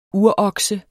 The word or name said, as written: urokse